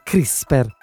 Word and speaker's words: CRISPR: Si pronuncia in realtà CRISPR la tecnologia, C-R-I-S-P-R.